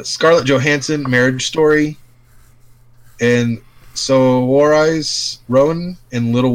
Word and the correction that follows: Saoirse: So, that name is pronounced Saoirse. – I'm sorry, Saoirse? – Yep. – S-A-O-I-R-S-E? – Yep. – Saoirse? – Saoirse. – Saoirse. – It's Irish.